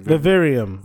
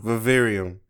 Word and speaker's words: Vivarium: Vivarium. – Vivarium.